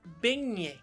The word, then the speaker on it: Beignet: Calma é que o Google vai dizer pra gente a pronúncia correta. Beignet. Benhé. Benhé. – Parabéns. É isso.